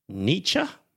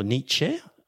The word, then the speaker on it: Nietzsche: Then there's Friedrich Nietzsche. Nietzsche or Nietzsche? I think it's Nietzsche, because when I went on Google how to pronounce, so you would have seen it written, dear listener, Nietzsche.